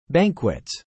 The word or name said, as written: banquets